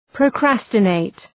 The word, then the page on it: procrastinate: prəʋ'kræstə,neıt